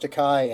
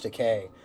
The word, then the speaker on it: Takei: that was driving me nuts last episode, because you guys kept saying George Takei, and it's George Takei. – I'm sorry. That makes me crazy, too. – And I only know that because George Takei says, you know, because it rhymes with gay.